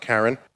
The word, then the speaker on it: Charon: And so, of course, Pluto has its large moon, Charon. Mm-hmm. And I think it also can be pronounced Charon.